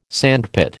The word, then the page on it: sandpit: American English